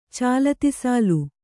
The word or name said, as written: ಚಾಲತಿಸಾಲು